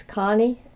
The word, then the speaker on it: Connie: My name is Connie Avon Petty. It's C-O-N-N-I-E A-V-O-N-N-E P-E-T-T-Y, and I live near Delight, Arkansas, in Pike County.